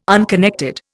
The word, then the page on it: unconnected: Indian